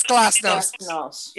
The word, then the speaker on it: Glasnost: Glasnost or something. I can't pronounce it right. – You are right. It is Glasnost.